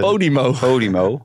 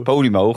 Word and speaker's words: Podimo: Nou, dat gaat wel komen, want dat Babibo, of hoe heet het? – Podimo. – Ja, dat gaat jou... – Je moet het wel goed uitspreken, de Podimo. Ja, Podimo gaat Hein Keizer benaderen.